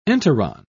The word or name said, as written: enteron